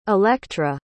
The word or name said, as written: electra